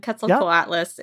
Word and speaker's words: Quetzalcoatlus: Parasaurolophus is the second one and Quetzalcoatlus is the third one.